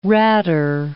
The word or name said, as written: ratter